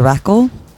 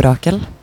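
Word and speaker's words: Rakel: So the name is composed of two parts. So it's Radio and Rackle, which is a women's name, obviously. I think most people, myself included, pronounce it in two different ways, depending on who we're talking to, I think. In Norwegian, I'd say Radio Rackle, Radio Rackle, just like in one word.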